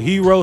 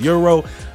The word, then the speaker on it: gyro: The weekend Linkin Park Gyro Fest starts tomorrow at St. George Greek Orthodox Church. I know some of y'all pronounce it hero, some of y'all pronounce it euro. I pronounce it gyro, so it's the Gyro Fest around here.